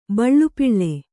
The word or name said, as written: ಬಳ್ಳುಪಿಳ್ಳೆ